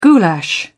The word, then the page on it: goulash: British English